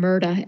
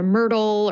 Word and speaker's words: Myrta: The young woman is named Myrta, M-Y-R-T-A. But no one pronounces it right. And that's sort of a joke in the book. People call her Myrtle or Myrna or whatever, but it's Myrta.